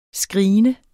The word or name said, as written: screene